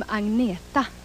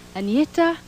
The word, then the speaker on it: Agneta: Hello, I'm Agneta. I know it's very hard for you to pronounce, but you can say Anita, or you can say Anna.